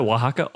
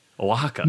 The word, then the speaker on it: Oaxaca: Well, that's how you pronounce that, Oaxaca, O-A-X-A-K-C-A, Oaxaca.